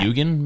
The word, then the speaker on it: Eugen: They went to this lecture for a rocket engineer named Eugene Sanger. – Eugene. – You know what? I think I forgot the E, but no, I think it was spelled like that. – Eugen, maybe? – Eugen? – Okay, well, Eugen Sanger. – E-U-G-E-N.